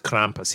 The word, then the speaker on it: Krampus: Krampus. You must say it like the Austrian, because it's an Austrian character. – It is. – So it's Arnold Schwarzenegger? – Yeah. Oh, he knows all about Krampus.